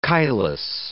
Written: chylous

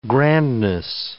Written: grandness

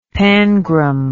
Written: pangram

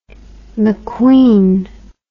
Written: McQueen

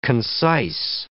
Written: concise

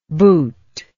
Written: bhut

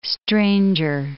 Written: stranger

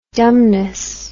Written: dumbness

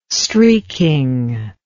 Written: streeking

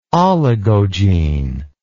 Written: oligogene

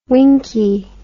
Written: winky

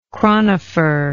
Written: chronopher

